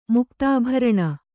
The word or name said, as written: ಮುಕ್ತಾಭರಣ